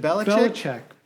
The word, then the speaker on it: Belichick: I'm a Bill Belichick fan, but not a Bill Belichick fan. – Oh, my God. – How do you pronounce his name? – What's happening? Now I'm too close. Yes. I'm popping. – You're popping. – Is it Belichick? – Belichick.